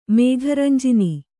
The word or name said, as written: ಮೇಘರಂಜಿನಿ